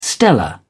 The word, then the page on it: stellar: British English